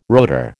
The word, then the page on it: rotor: American English